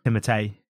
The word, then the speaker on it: Timothée: Also, we've been mispronouncing Timothy Chalamet's name. – How is it meant to be pronounced? – Timothée. – Oh, really? I've heard Timothy for most people. – Yeah, most people anglicize his name.